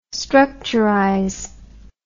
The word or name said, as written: structurize